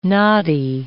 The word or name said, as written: noddy